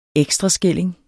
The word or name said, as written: ekstraskilling